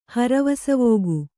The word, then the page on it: ಹರವಸವೋಗು: kannada